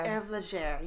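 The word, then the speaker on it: Hervé Léger: 1990s Herve Léger. I feel like I butchered how you pronounce that. I feel like that's... – Herve Léger, yes.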